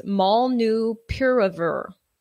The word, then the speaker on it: Molnupiravir: Molnupiravir.